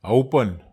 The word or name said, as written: aupan